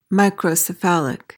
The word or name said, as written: microcephalic